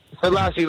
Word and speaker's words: Selassie: Selassie, like Haley Selassie.